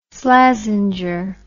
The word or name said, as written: Slazenger